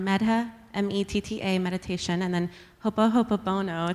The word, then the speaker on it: ho'oponopono: Something I can also offer is metta meditation and ho'oponopono.